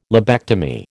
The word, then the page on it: lobectomy: American English